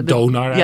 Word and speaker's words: Donar: En jij noemde net al, even kijken of ik het goed kan uitspreken hoor. Je had het over Thonar ja, uit de Germaanse ja, mythologie. Dat, hè? – Of, ja, ja Donar ja, eigenlijk die, gewoon.